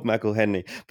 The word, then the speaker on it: McElhenney: Rob McElhenney, is that how you pronounce it? – McElhenney, yeah, that's right.